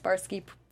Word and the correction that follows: Swarovski: Crystals. – Oh, Swarovski. – Swarovski. Yeah. Cool.